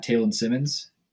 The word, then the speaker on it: Taylin Simmonds: For a plug, I would say Twitter, like just at Taylin Simmonds.